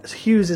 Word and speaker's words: Hughes: That's Hughes is spelled H-U-G-H-E-S.